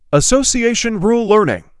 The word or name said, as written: Association rule learning